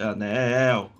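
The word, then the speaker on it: ANEEL: É, é Anil ou Anel? Como é que fala? É anel? Como é que é? – Eu falo, eu falo anel.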